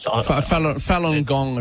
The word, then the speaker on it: Falun Gong: There's about 60-odd people down there at the moment holding banners saying stop the persecution of Fung Guan, I think it's pronounced. – Falun Gong, it'll be.